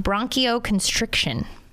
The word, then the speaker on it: bronchoconstriction: Ultimately, we're talking about bronchoconstriction. Did I say that right? – That's good.